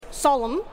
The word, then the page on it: sol-m: ^sol-m